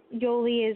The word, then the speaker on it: Yoly: Lady Yoli is L-A-D-Y, and Yoli is spelled Y-O-L-Y.